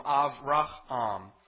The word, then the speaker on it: Avraham: In Hebrew, it would be pronounced Av. And so Avram is different from Avraham.